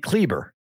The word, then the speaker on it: Kleber: Say his name again. – Maxi Kleber, K-L-E-B-E-R. – Maxi. – Maxi, M-A-X-I.